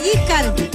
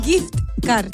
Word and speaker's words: gift card: pastilla, Giscard. Oye, y no se dice Giscard, se dice gift card.